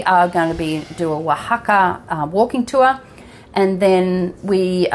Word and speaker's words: Oaxaca: I love Oaxaca. It's spelt O-A-X-A-C-A. It's crazy, isn't it? So Oaxaca.